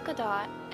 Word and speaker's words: Gadot: As many of the fans are familiar, Gal Gadot, and yes, the T is pronounced, is an Israeli.